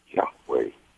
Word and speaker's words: Yahweh: which I understand most experts say that's pronounced Yahweh.